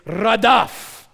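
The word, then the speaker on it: radaf (רדף): how to pronounce it so you don't look stupid. It's radaf.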